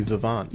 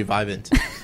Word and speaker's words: Vivant: She also got to go to Brewery Vivant. – Yeah, there you go. – She pronounced it correctly. Unlike when Chuck Todd went there, he said Brewery Vivant.